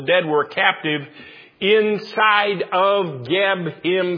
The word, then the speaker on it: Geb: The Lord tells him, smite the dust of the land, and this is an attack on Geb. Sometimes it's spelled Keb, and sometimes it's pronounced Seb. But who is Geb?